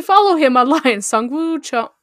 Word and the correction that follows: Sungwon Cho: or Sungwon Cho. I already messed it up. I'm like, I know his name. Prozd. So I said I know how to pronounce his name, and then I pronounced it wrong anyway.